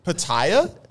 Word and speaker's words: pitaya: The one we had last, the pitaya berry. How do you say that? – Pitaya? – Pitaya? – Yeah.